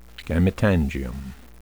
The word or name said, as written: gametangium